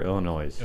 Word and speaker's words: Illinois: Illinois, is it pronounced Illinois or Illinois? – Illinois. – Illinois? Okay. I always read it as Illinois, and I feel like anyway.